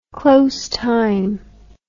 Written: close time

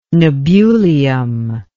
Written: nebulium

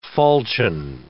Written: falchion

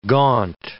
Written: gaunt

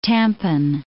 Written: Tampan

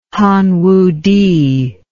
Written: hanwuti

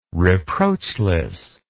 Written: reproachless

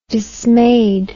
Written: dismayed

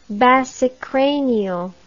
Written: basicranial

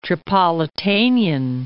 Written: Tripolitanian

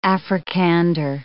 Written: Afrikander

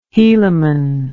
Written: hielamon

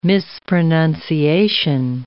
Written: mispronunciation

